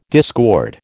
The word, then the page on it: discord: American English